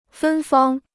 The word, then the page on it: 芬芳: fēn fāng